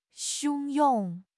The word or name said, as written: 汹涌